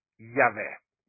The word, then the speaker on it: Yahweh: Che diciamo nell'originale è il tetragramma, però pronunziato appunto si pronuncia Yahweh, il solo vero Dio, il Dio d'Abramo, di Isacco e di Giacobbe.